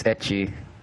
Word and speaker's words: ecchi: Obviously loves ecchi. – Dude, everyone does. You're not a man. – Wait, just for the record, is it ecchi or ecchi? – It's ecchi.